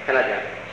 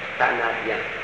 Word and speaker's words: canadien: Monsieur le membre des chambres du Parlement canadien. How do you say it? – Canadien. You don't chop it up quite that bad. It's Canadien.